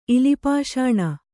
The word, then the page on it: ಇಲಿಪಾಷಾಣ: ilipāṣāṇa; Kannada